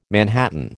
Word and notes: manhattan: American English

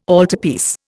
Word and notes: altarpiece: Indian

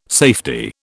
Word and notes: safety: British English

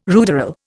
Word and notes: ruderal: Indian

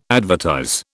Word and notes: advertise: British English

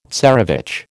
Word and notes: tsarevich: American English

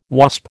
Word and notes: Wasp: American English

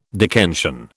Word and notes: Dickensian: British English

